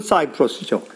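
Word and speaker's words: Cyprus: 그 대표적인 예로 최근에 있는 그 키프로스 나라의 사태입니다. 여러분, 그 영어 발음으로는 사이프러스죠.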